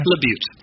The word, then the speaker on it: LaBute: The Way We Get by Neil LaBeau. Am I saying that right? – LaBute. – LaBute.